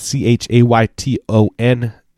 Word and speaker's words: Chayton: Mine is Chayton underscore Peterson. That's C H A Y T O N. Chayton, not Clayton.